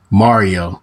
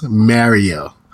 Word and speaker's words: Mario: He got onto me for saying Mario, which is the way I learned it from the video game. – Sure. – But he told me it's pronounced Mario.